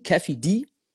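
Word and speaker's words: Keffe D: Da haben die einen Kerl namens Kefi D, glaube ich, spricht man den aus. K-E-F-F-E und dann D, Kefi D, festgenommen.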